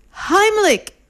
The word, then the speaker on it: Heimlich: Heimlich is spelled H-E-I-M-L-I, hold on to your chair for this one, C-H, Heimlich, H-E-I-M-L-I-C-H. So the C-H come together to make that K sound, so there is no K in the word Heimlich.